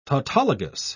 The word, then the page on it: tautologous: British English